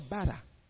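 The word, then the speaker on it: bara (ברא): Le mot « créer » ici, c'est le mot « bara » que l'on prononce en hébreu « bara ». B-A-R-A.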